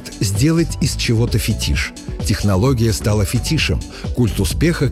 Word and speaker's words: фетиш: В этом слове ударение иногда ставят по ошибке. Переносят на первый слог – фетиш. Но литературная норма однозначна, и словари единодушны – фетиш с ударением на последнем слоге. Это объясняется происхождением. Слово пришло в русский язык из французского, где ударение обычно падает на последний слог. Русский вариант это ударение сохранил – фетиш.